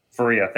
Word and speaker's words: Faria: Um, I don't think we were as fortunate uh, with this series, and the dud for me would be Jake uh, Faria. Faria. I'm not entirely sure if I'm pronouncing Faria. that correctly. – Faria. Thank you.